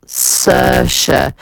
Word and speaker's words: Saoirse: Now, it is meant to be with a Sir, Saoirse, but the spelling, a lot of Irish names have got such crazy spellings, haven't they?